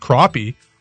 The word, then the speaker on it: crappie: Crappie, pronounced crappie, are a delicious eating panfish found throughout central to southern Ontario.